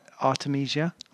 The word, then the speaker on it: Artemisia: This is Spider by Artemisia.